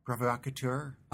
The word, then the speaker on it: provocateur: Provocateur? How do you say that? – Provocateur.